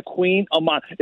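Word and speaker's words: Kouign-amann: It's not spelled like king and queen. It's spelled with like a K and then like a Haman. It looks like Queen of Haman. And it's always funny hearing people like pronounce it and then they always correct you. Oh, it's Queen of Mons.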